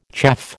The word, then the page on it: chaff: American English